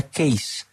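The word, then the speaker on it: Kees: Creo que ese nombre se escribe con doble E, pero se pronuncia Case.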